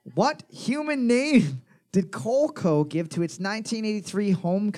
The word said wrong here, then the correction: Coleco: Coleco. Not everything that has your first fucking name in it is pronounced like that.